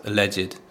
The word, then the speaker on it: alleged: So we can use here the word alleged. Sometimes you'll hear it pronounced alleged, but they both mean the same thing.